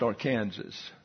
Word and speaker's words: Arkansas: and it's pronounced Arkansas. It doesn't get to be Arkansas until it gets into Arkansas. Now, I don't know why the people in Arkansas decided to change the pronunciation, but that is their business. But we're not in Arkansas in our study, so it's the Arkansas River.